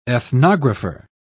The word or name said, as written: ethnographer